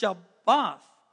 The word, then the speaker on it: Shabbat: That word is pronounced Shabbat, the Lord's day.